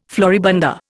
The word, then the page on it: floribunda: Indian